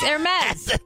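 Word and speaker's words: Hermès: You don't even say that correctly, I don't think. I mean, I don't know how to say it correctly. – It's Hermes. It's Hermes.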